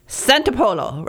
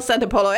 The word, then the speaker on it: Santopolo: And it's pronounced Santopolo, right? – Santopolo, like Marco Polo. – Okay. Jill Santopolo.